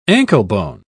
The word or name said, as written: anklebone